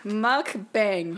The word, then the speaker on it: mukbang: Mukbang. – How do you say bang? – Bang. – Okay, muck. – Muck. – Mukbang. – Mukbang.